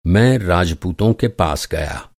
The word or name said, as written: राजपूत